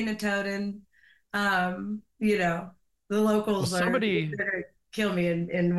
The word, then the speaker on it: Notodden: Notodden.